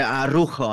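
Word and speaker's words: Araújo: It was the red card, Araujo, or as they pronounce it here, Araujo. I think it's easier, so they just cut out the middle A.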